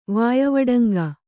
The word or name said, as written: ವಾಯಿವಡಂಗ